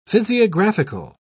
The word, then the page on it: physiographical: British English